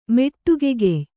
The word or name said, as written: ಮೆಟ್ಟುಗೆಗೆಯ್